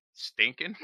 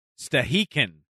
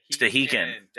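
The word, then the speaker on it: Stehekin: Pronunciation key says Stahikin. – Stahikin. – Stahikin. – It's Stahikin.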